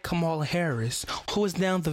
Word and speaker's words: Kamala: First of all, you ain't even saying her name right. Kamala. – Kamala.